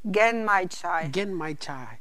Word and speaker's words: Genmaicha: And how do I pronounce this? Genmaicha tea? – Genmaicha. It's tea with rice.